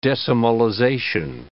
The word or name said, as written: decimalization